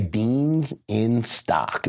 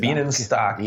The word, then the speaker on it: Bienenstock: Sorry, how do you pronounce his last name? – I just say Beans in Stock. – David Bean in Stock.